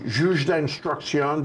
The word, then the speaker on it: juge d'instruction: It gets to a complaint, the juge d'instruction, did I say that right? – Mm-hmm.